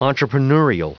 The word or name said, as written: entrepreneurial